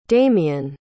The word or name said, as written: dameon